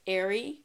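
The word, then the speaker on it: Aire: I didn't know if it was going to be, like, Airy. Like, Stormy and Airy. But she clarified it's just pronounced like Air.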